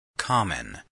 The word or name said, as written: common